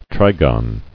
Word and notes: Trigon: tri·gon

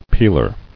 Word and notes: Peeler: peel·er

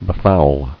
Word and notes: Befouled: be·foul

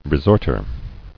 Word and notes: Resorter: re·sort·er

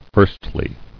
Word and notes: Firstly: first·ly

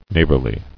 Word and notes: neighborliness: neigh′bor·li·ness

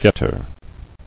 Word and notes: Getter: get·ter

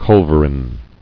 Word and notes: Culverin: cul·ver·in

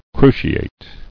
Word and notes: Cruciate: cru·ci·ate

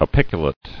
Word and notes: Apiculate: a·pic·u·late

